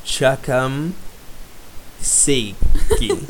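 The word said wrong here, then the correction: Kocham Cię: So, Kocham Che.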